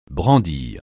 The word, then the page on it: brandir: francais